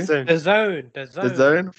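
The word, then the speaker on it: DAZN: I think the NFL have made a deal with, is it Danzen? I can't say the name properly. – The Zone. – Thank you. The Zone.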